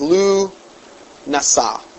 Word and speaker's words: Lughnasadh: I must also mention that that this point that August 1st of every year is the High Cross Quarter Sabbat of witchcraft, known as Lughnasadh, pronounced as Lu Nasa.